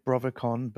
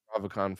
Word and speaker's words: Brovicon: And am I pronouncing it right? Brovacon? – I've never actually thought about it. I've said Brovacon, but it doesn't really matter, I guess. – Well, I'll say Brovacon from now on then.